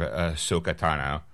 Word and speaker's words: Ahsoka Tano: Okay, well, since you brought it up, there's little doubt that Star Wars fans are eager to see more of Ahsoka Tano.